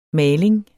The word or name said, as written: maling